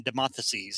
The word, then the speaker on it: Demosthenes: But what I love about it is when the government finally figures it out, because they know of these two personas, Locke and Demosthenes. Isn't that how you pronounce it? – Yeah. – About how they're arguing all the time,